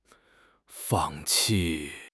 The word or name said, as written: fang4 qi4